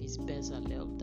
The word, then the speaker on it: Bezalel: I don't know, I think it's Bezalel, I believe. Bezalel, that's how it's pronounced.